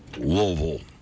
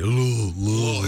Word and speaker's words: Louisville: The first one is an audio clip from, it's the second time today we've gone to Louisville. Is that pronounced? That's the way you say it. Louisville. – It's kind of like you got something in your throat.